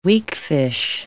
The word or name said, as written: weakfish